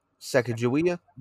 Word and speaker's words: Sacagawea: Sacagawea? – There you go.